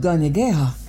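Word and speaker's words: Kanyen'kéha: The Mohawk language is called Gonyageha, and yep, you guessed it, those G sounds are actually spelled with K's.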